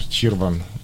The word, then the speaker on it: Чирван: И басист Виктор Чирван. Ударение на первый слог, Витя.